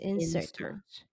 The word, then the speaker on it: insert: 如果是嗯，如果是 noun，你说它发音是 insert，啊，重音要在前面。